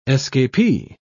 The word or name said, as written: escapee